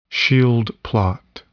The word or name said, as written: Schild plot